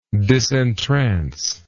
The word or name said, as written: disentrancement